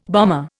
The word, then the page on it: bomber: Indian